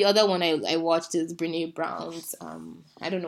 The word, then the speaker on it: Brené: It's Brené.